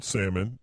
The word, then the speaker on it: salmon: White cheddar, grilled salmon, and strawberry shortcake. – Yeah, so I'd be willing to try any of that. Also, side note, do you know anyone who pronounces the L in salmon? – I don't.